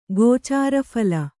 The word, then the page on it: ಗೋಚಾರಫಲ: Kannada